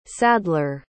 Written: sadler

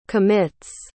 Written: commits

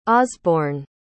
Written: osbourne

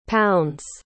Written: pounds

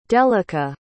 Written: delicah

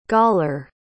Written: galler

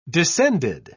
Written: descended

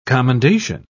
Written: commendation

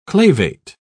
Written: clavate

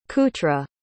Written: kouta

